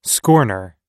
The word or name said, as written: scorner